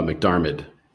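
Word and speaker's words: McDiarmid: How do you say his last name? – McDarmid. – McDarmid? I think there's an end at the end, is there? – Oh, McDarmid. Sorry.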